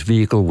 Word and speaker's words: vehicle: Their preferred pronunciation is vehicle without the H. – Yep. – They say with the H as an alternative, but they list them in that order. So the preferred pronunciation is without the H, and calling it vehicle, they say you can do that if you want to, but clearly...